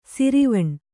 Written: ಶರವಣ

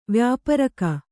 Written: ವ್ಯಾಪಾರಿಗ

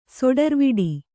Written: ಸುಟ್ಟುರೆವಿಡು

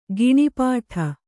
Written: ಘನಪಾಠ

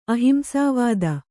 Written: ಅಹಿಂಸಾವಾದ

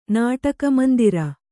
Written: ನಾಟಕಮಂದಿರ

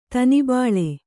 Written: ದನಬಳ

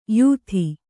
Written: ಯುತಿ

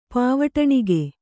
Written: ಪಾವಟಣಿಗೆ

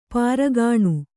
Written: ಪೀರಖಾನಿ

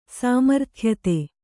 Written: ಸಾಮರ್ಥ್ಯತೆ

